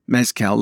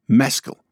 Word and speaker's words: Mescal: It's not mezcal like the tequila drink where the emphasis is on the last syllable, but mezcal. With a soft ending.